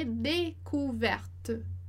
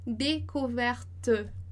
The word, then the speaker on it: découverte: D com som de E, découverte. D, E, por causa do acento, então, découverte. E o último E que é mudo porque ele não tem acento.